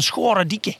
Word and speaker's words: Scharendijke: In, Op de Zeeuwse uitspraak. En dat is iets van Schoredieke.